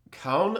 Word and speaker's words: Calne: And when he did, he moved to Calne, I believe it's pronounced. C-A-L-N-E.